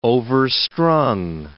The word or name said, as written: overstrung